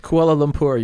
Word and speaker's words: Kuala Lumpur: Forgive me if I mispronounce this. Kuala Lumpur. – Kuala Lumpur. You were so close. – You were close.